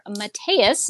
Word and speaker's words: Mateus: and colored by Matt or Mateus. So that's how he told me to pronounce his name. Wanted to make sure that that was correct.